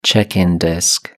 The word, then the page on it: check-in desk: British English